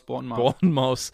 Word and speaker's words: Bournemouth: Wie spricht man die aus? – Bournemouth. – Dann Born Englisch und dann Maus Englisch.